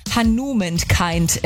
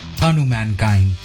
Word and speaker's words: Hanumankind: Hanumankind entstand. Und naja, dieser Name Hanumankind, keiner weiß so wirklich, wie man ihn ausspricht. Deswegen, lass uns doch mal reinhören. Ein einziges Mal. Wie wird's richtig ausgesprochen? – Hanumankind.